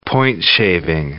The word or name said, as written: point-shaving